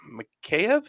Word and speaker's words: Mikheyev: Because then Quinn Hughes scores on the PP at 13:21, putting Vancouver up 2-1, followed by Ilya Mikheyev. Is that how you say it? – You nailed that one. Yes.